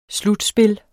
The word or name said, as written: slutspil